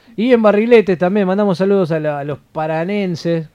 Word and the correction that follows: paranaenses: ¿Cómo se dice? – Sí. – Paranenses. – Paranaenses. – Paranaenses, ahí está, me sonaba raro.